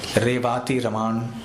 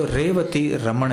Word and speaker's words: रेवती रमण: विदेश के लोग जैसे अ को आ कह देते हैं देवाकी, लेकिन है देवकी, रेवाती रमान कहते हैं, तो रेवती रमण है,